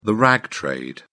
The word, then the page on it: rag trade: British English